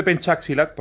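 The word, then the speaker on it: pencak silat: Bueno, el, el, que, el, estilo de pencaxilat, porque se pronuncia así, son así de, de raros estos indonesios.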